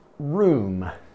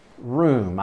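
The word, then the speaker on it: rheum: It's actually called room. Room. I even had to check the pronunciation because it's not R-O-O-M. R-H-E-U-M. Room.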